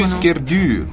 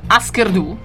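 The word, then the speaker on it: Hüsker Dü: Husker Du, pronuncia per favore il corretto Husker norvegese, sì, che è un po', diciamo.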